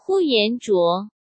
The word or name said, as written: Huyan Zhuo